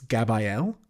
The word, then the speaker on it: Gabrielle: Don't know why she keeps mispronouncing her name. It's Gabrielle.